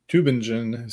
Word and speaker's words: Tübingen: It's a very respectable university, but my ability to pronounce T U umlaut B I N G E N isn't uh, isn't great. So Tübingen is how I think it's pronounced, and it's more Seib- uh, I guess, it's pronounced in the German pronunciation.